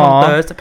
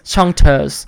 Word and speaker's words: Chanteuse: ภาษาอังกฤษอ่านว่า Chanteuse ภาษาภาษาฝรั่งเศสอ่านว่า Chanteuse ภาษาอังกฤษจะอ่านออกว่า Chanteuse